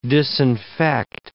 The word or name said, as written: disinfect